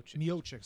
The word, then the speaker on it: Miocic: Miocic.